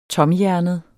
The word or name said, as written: TOMHJERNET